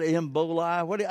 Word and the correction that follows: Ebola: How do you pronounce it? Yeah. How do you pronounce it? Ebola.